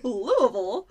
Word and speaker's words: Louisville: I was going to say, I'm so happy that you know how to pronounce it, because a lot of people outside of Louisville say it like Louisville or Louisville or something like that. Yes, I understand it was named after King Louis. – Yeah. – But it's you got to let it roll off the tongue a little bit.